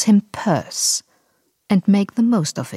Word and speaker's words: Pierce: Präsident der Vereinigten Staaten und der einzige, der aus New Hampshire kam, muss natürlich nur deshalb purse ausgesprochen werden, damit er sich wohl oder übel auf worse reimt.